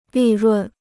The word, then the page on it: 利润: lì rùn